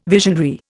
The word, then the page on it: visionary: Indian